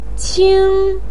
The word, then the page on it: qing: 1